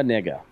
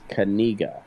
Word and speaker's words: Knigga: So this kid's name is Noah, as it's spelled, Noah Nigga, KNIGGA. – Oh, the K is silent. – No, yeah. I guess they have a tutorial on how to say it, and it's Kaniga.